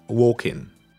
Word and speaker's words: walking: You write the L, the L, but you don't pronounce it. Just walking.